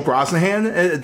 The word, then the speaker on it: Brosnahan: Rachel Brosnahan, that's how you pronounce it, right? Mm-hmm. Yeah.